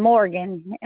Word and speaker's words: Morgan: E-R-N-I-C-E. And then Morgan. M-O-R-G-A-N.